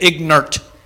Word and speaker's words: ignorant: Now you got to say it, ignorant. It's two syllables.